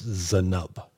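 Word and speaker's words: Zainab: It's Z-A-I-N-A-B, but according to their press release, it's Zenub.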